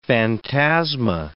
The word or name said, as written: phantasma